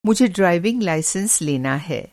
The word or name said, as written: लाइसेंस